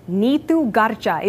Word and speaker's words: Neetu Garcha: This is how my name is spelled, but there is a right way to say it. Neetu Garcha is the authentic Punjabi pronunciation that my parents gave me rather than Neetu Garcha. Like so many, I created the latter anglicized version during my childhood, much of which was spent just trying to fit in.